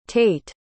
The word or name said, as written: taite